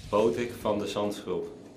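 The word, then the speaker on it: Botic van de Zandschulp: His first name is Bodic, but it's spelled, the last name is spelled, or well, it's Bodic Van D, V-A-N-D, and then the last name, Z-A-N-D-S-C-H-U-L-P. You think that's easy enough, but here is Bodic himself. This is how you say his name. – Bodic van de Zandschulp.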